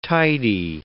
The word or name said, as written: tidy